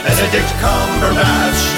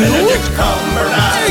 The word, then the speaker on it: Benedict Cumberbatch: Get the name right. – Benedict Cumberbatch. – Thank you. – Benedict Cumberbatch. – There you go. – Alley-oop, Cole, and Bath. – No, it's Higgs. – Benedict Cumberbatch.